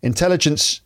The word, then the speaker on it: intelligence: Artificial intelligence, intelligence, the stress is on the second syllable, but it's put it on the first syllable, allows users to interact with a virtual assistant.